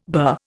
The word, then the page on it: bur: Indian